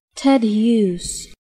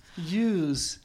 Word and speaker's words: Hughes: Sorry, er is maar één juiste uitspraak en dat is... – Oh! – En dan krijgen we hem nu te horen. – Ted Hughes. – Hughes.